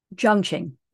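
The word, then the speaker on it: Jiang Qing: Jiang Qin.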